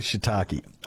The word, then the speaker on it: shiitake: I believe it's shiitake, but I'm sure there's probably a Japanese person listening and cringing that I've pronounced okay. – it wrongly. – Shiitake, I'm going to go with that.